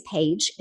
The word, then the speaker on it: Paige: My name is Paige and it's spelled P-A-I-G-E. But if you take out the I, P-A-G-E, you've probably heard of a page.